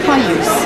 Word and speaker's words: Caius: So instead of being any way of spelling Dorky, so K-E-Y-K-E-E, it became C-A-I-U-S, Caius. But it's still pronounced Caius because his name was Caius.